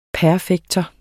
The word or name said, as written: perfektum